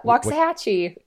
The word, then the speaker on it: Waxahachie: Waxahachie.